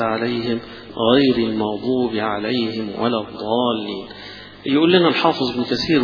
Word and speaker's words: الضالين: لكن لا تنطق الضاد ضاء زي ما البعض يقول لك هي هذا مخرجها هذا وتقرأ، ولا الضالين يقرأها قريبا من الضاد أو يقرأها كالضاد، هذا ليس بصواب.